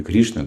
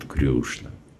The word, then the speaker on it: Кришна: На Ории они не Кришна, а говорят Крюшна.